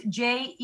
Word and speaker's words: Jeddah: And you're saying Jeddah, or? – Yeah. – Am I saying that right? – Yeah, Jeddah. So it's Jeddah.